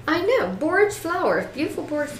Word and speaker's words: borage: It's actually spelled B-O-R-A-G-E, so I looked it up, and and that's what they said. You pronounce it borage. Sounds like porridge.